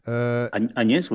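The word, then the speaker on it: Áñez: par Mme Yannine je ne sais pas si je le prononce comme il faut. Agnès, oui. Agnès, bon voilà.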